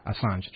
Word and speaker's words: Assange: We're speaking with, you know, I still don't have the last name pronunciation. – Assange.